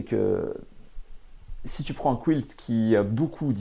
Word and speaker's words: quilt: Alors quilt, on le prononce quilt. Mais bien sûr, j'avoue être, j'avoue, oui, j'avoue être grand fan du quilt et même dans sa prononciation francophone, le kilt, je trouve ça très bien.